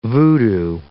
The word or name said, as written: voodoo